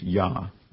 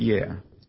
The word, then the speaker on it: Yah: The Yod and the He that are found in his name is to be pronounced Yah, not Yeh, Y-A-H, not Y-E-H.